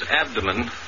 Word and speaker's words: abdomen: Around his abdomen, the chieftain wore a cloth of brilliant orange. – The word? Abdomen. Accent on the second syllable, in which the O is long.